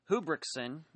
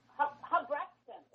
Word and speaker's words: Hubrechtsen: And just, if I may, for the listeners here, she is talking about Yvonne Hubrickson, and you spell the name H-U-B-R-E-C-H-T-S-E-N. – Who, it's actually pronounced Yvonne Hubrickson.